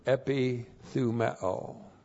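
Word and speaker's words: epithumeo (ἐπιθυμέω): And then we have the verb epithumeo. So this is the verb. E-P-I-T-H-U-M-E, long O, epithumeo.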